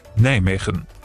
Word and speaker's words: Nijmegen: Richtig ausgesprochen, die Niederländer sagen so. – Nijmegen.